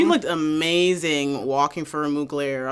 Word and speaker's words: Mugler: Dominic Jackson for Mueller. Bitch. – Mugler.